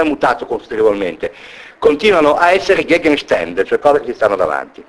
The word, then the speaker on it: Gegenstände: ma a volte modificando la vocale tematica, quindi da A diventa E, una con le si pronunciare una E, toccare una E, quel Gegenstände,